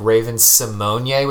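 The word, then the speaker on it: Raven-Symoné: Like, you have someone like now, like Raven Simonier, which I think is how you're supposed to be pronouncing it.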